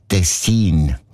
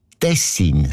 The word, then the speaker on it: Tessin: Also klar, Tessin. Aber der SRF-1-Hörerin Regula Stern-Grieser von Locarno ist aufgefallen, dass gewisse Leute statt Tessin Tessin sagen, also Betonung auf mehr, mit einem kurzen I.